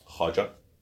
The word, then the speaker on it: Khwaja: Am I saying this right? Khwaja? – Yeah. – Khwaja Ghulam Farid. – I mean, the W is a little bit more subtle. Khwaja.